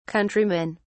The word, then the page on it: countrymen: British English